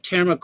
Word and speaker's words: Terma: How do you say that? – T-E-R-M-A, Terma.